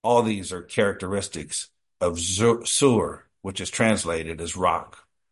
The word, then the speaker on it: Tzur: Sur, T-Z-U-R, pronounced S-O-O-R.